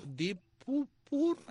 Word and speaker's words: Deep Purple: Deep Purple.